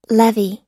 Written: levee

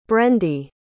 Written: brendy

